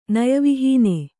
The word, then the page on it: ನಯವಿಹೀನ: naya vihīna